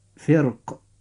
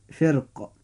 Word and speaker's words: فرق: كلمة فرق، هذه الراء في هذه الكلمة ساكنة وبعدها حرف من حروف الاستعلاء المكسور وهو حرف القاف، فيجب في هذه الحالة أن نرقق الراء عند النطق ولنا أيضا أن نفخمها، فتنطق هذه الكلمة هكذا: فرق، وذلك في حال التفخيم.